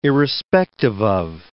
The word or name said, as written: irrespective of